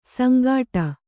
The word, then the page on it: ಸಂಗಾಟ: Kannada